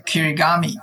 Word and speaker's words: kirigami: But it's a kirigami. – Kirigami. Okay. – It's like an origami. Origami, origami, right? – Yes, yes. – So kirigami is, ori means the fold.